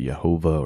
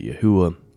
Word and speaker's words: Yehovah: I pronounce it Yehovah or Yehovah. Some people pronounce it Yahuwah. It's definitely up for debate.